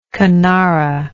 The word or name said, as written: canara